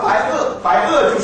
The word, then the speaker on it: 白垩: 那么白垩就是那种石头，那种的石头呢是 chalk。